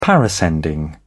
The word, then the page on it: parascending: British English